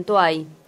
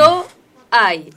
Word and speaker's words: Toay: Toay. Toay, lo dije bien, porque siempre digo Toay y después me cargan. – Arrancaste la U con U.